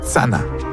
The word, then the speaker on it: channa: Par exemple, pour dire arrêt en Abénaki, on dit tsana. En Abénaki, ça s'écrit C-H-A-N-N-A, mais il n'y a pas le son C-H. À la place, on utilise le son T-S. Et donc, ça donne Tsana.